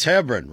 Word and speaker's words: Hebron: I think you guys call it Hebron, right? It's not LeBron with an H. Hebron. Lebron. It's Hebron, right?